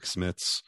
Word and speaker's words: Smits: It's all it could be was Rick Smits big and white rhymes with chicklets.